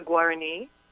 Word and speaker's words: Guarani: How do you say Guarani, that? Yes, it's the Guarani.